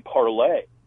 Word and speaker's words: Parler: And by the way, Parler, I think the original pronunciation was supposed to be Parlay. – Oh, interesting. Okay. – Yeah, but everybody calls it Parler because that's the way it's spelled.